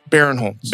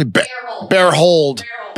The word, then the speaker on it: Barinholtz: Barinholtz. – Barinholtz. I was on Howard Stern this morning and Tam Mom kept calling me. – What was she calling me? – Barehold.